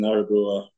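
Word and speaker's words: Örebro: Let's see, you played with Arebro first. Am I saying that right? – Yeah. First in Arebro.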